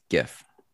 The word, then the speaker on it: GIF: GIF with a hard G. GIF. – GIF. – Not a GIF like the peanut butter. – No, because that's with a J.